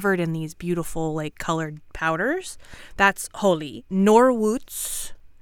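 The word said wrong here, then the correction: Noruz (نوروز): No, wait. Hold on. I wrote this one out phonetically because it really screwed up my brain. Noruz. Yes, Noruz,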